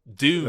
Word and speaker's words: Dune: All right, June. Dune. – Okay. – To pronounce it correctly, Dune. As the original author wrote it.